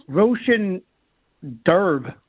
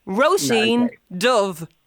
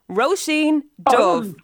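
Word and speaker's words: Róisín Dubh: So, Roshan Derb. – Oh, no. – Is that anywhere near? – No. Roisin Dove. Roisin Dove. You know, like versus the Dove. So think of Dove like as in the bird, or the, you know, the skincare, the soap, and all that. But it's the BHs in Irish are pronounced like a V.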